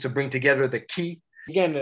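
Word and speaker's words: ki (気): In Japanese, it's pronounced ki.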